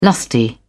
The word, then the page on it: lusty: British English